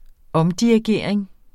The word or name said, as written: omdirigering